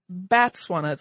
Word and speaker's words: Batswana: A person from Botswana is called a Motswana, and the plural of that is Batswana, so that's, I'm maybe pronouncing it incorrectly, but it's a B-A-T, not a B-O-T.